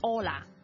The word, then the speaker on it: Hola: 咁喺西班牙文呢我哋就會讀 hola。 – Hola，系啦，咁咧再简单教一教大家西班牙文嘅发音规则。咁 Hola 咧，佢系串 H O L A 嘅，但喺西班牙文咧 H 咧系永远都系 silent，即系唔发音嘅。